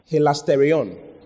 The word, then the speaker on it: hilasterion: So, this particular one is hilasterion. Hilasterion is spelled H-I-L-A-S-T-E-R-I-O-N. Hilasterion. H-I-L-A-S-T-E-R-I-O-N. Hilasterion. Okay?